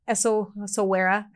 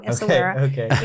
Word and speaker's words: Essaouira: You can go to Morocco and go to Essaouira. I say it wrong. Essaouira in Morocco.